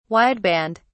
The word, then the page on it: wideband: British English